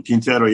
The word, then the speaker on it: Quintero: Quintero.